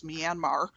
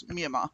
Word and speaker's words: Myanmar: It's spelled M Y A N M A R, which we here in America would pronounce Myanmar. Um, but in Burma, they actually drop pretty much all ending consonants. so it's it's Myanmar is how you'll hear them pronounce it.